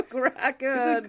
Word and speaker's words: Kraken: Sorry, Mickey, it has to be pronounced, release the Kraken.